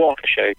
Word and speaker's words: Waukesha: Waukesha, yeah.